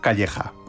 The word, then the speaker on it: Calleja: Y luego también, bueno, pues hay un tenor muy célebre en el mundo, que es, yo lo voy a pronunciar a la española porque creo que tiene ancestros de nuestro país, que es Josef Calleja, aunque creo que ahí lo dicen Josef Calella, pero Calella no, Calella está en otro sitio.